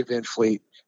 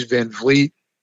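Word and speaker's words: VanVleet: Fred Van Vliet, impossible to pronounce. You always want to say Van Vliet, but it is Van Vliet.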